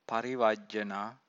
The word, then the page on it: parivajjana: Pali